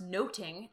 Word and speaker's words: nothing: Two, nothing would have been pronounced noting at the time, which also meant eavesdropping.